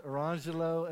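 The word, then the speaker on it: Orangejello: They were spelled like this. Orange Jello and Lemon Jello, but it was pronounced Arangelo and Lamangelo.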